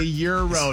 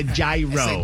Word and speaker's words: gyro: That's a euro. – That is a euro, not a gyro.